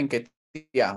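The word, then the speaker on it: Nketiah: por ahí dicen Enquetia, pero bueno, una vez un aficionado de Ghana que llegó por ahí a la transmisión dice, primera vez que escucho bien pronunciado el nombre de Enquetia, así que yo le sigo diciendo Enquetia, por más que no tenga tilde.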